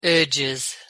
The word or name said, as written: urges